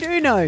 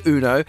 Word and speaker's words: Uno: People have been texting through saying it's Uno. It's not. Uno. – Uno. Yeah. Okay. It's the Spanish pronunciation. Uno.